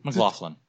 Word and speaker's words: McLaughlin: McLaughlin.